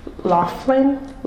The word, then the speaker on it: Laughlin: The model law designed by eugenicist Harry Laughlin, that's how you pronounce it, defined socially inadequate classes of people very broadly.